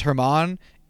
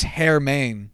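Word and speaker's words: Germán: Apparently, oh, it's not pronounced Herman. It's Hermain, or some shit like that. – Hermain. – Yeah. – What? – Like Hermaine close, it, like like it was closer to Germain than it was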